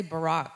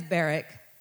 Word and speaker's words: Barak: I kept wanting to say Barack or whatever. It's Barak. I kept hearing it.